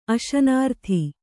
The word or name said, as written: ಅಶನಾರ್ಥಿ